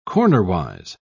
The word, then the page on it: cornerwise: British English